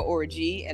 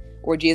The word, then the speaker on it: Orji: So on Twitter, I am at Keturah Orji, and I'll spell that again one more time. Keturah is K-E-T-U-R-A-H or G-O-R-J-I. Orji is a Nigerian last name, by the way.